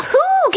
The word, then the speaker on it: Hoog: It's Charlotta Hoog, is it not? – Charlotta who? – Hoog rhymes with bug.